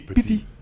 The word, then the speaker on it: Petit: Petit. I think you got to say it like that.